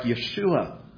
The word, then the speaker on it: Yeshua: First thing that we have to understand, not a lot of people seem to remember this, is that the name Jesus, as I am saying it now, is not the pronunciation of our Lord's given name, which is more like Yeshua.